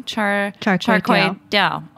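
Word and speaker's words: char kway teow: So how do I pronounce that actually? – Char kway dao. – Mm-hmm. Dao, okay. So but it is a Daoswa food.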